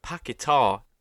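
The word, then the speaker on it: Paquetá: Um, and then Paqueta scores, or Paqueta is actually how you should say his name, but Paqueta just sounds better.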